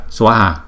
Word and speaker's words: Swaha: Hai chữ A có đều có dấu gạch ngang thì chúng ta đọc kéo dài hai nhịp hết, cho nên chúng ta kéo dài hai cái âm này. Swaha, thấy không? Chứ chúng ta không đọc là Swaha mà chúng ta đọc là Swaha. Nếu chúng ta đọc bình thường á thì là Swaha đó. Còn cái này chúng ta trì cho nên chúng ta kéo dài hơn hai nhịp,